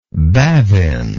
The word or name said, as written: bavin